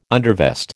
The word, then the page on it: undervest: American English